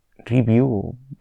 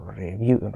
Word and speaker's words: Revue: ahora seguimos con la siguiente novedad de la compañía y es que ayer Twitter, bueno, pues anunció la, compra de Review, Review, se escribe Revue, R-E-V-U-E, e, que al parecer es una conocida plataforma de newsletter de la que yo no había oído hablar.